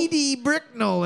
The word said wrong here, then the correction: Edie Brickell: and the New Bohemians. Edie Brickell and the New Bohemians.